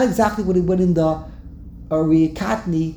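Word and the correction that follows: Recanati: did I pronounce that correctly? – No, Rekinati.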